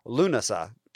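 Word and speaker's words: Lughnasadh: So, moving forward, welcome to the episode on Lammas, or Lunasa, as it is pronounced in the old Gaelic.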